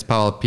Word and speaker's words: Pavol: So first name is Pavol, P-A-V-O-L. Last name is Sadecky, S-A-D-E-C-K-Y.